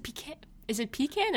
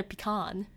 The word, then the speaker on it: pecan: Is it pecan or pecan? – I think you could pronounce it both ways.